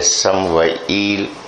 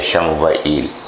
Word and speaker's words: سموئیل: 20 سال کے بعد اپنے دور کے نبی حضرت سموئیل اور دوسرا ایک تلفظ ہے شین کے ساتھ بھی ہے شموئیل, سموئیل اور شموئیل, شم عربی زبان میں اس کو سین کو شین بنا دیا گیا ہے.